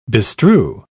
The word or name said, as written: bestrew